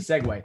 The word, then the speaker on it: segue: I can't spell it, but it is pronounced a segue.